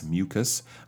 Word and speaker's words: mucus: Uh, so that's mucus, mucus, a hard K or hard C K sound um, and watery, meaning it's more more liquid, not so thick.